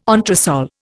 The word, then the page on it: entresol: Indian